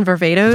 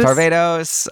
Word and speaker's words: Varvatos: John Varvatos. – Varvatos. – Varvatos.